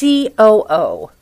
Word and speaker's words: COO: The Luna Cosmetics coup. – It's not pronounced coup, Travis. It's COO. It stands for Chief Operating Officer.